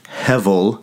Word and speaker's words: hevel: One of my favorite words that others have used too for describing this Hebrew word, hevel, H-E-B-E-L, pronounced hevel with a V.